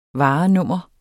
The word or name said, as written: varenummer